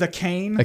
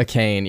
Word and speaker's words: Achane: I went with Achane. – A cane, yeah, okay.